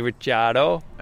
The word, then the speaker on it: Ricciardo: I said that earlier, but definitely Ricardo. – Well, you didn't pronounce it right, but that's fine. Yeah, Danny Ricciardo.